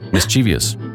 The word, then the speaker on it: mischievous: Yeah, look where the I is. Mischievous. – No. – It is? Yes. I've never heard it said that way before. – I have never said it that way. – Mischievous. Look at the way that it's spelled. Yeah, the I's before the V. – Oh. – It's not after it.